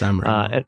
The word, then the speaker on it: Sanremo: Do hope I'm pronouncing that correctly. – Yes, Sanremo.